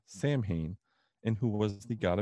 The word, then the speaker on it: Samhain: So the story of Halloween dates back thousands of years ago to ancient Celtic traditions and one specifically called Samhain. Now, Samhain is a Gaelic word and it's spelled S-A-M-H-A-I-N and is often mispronounced by many people as Samhain.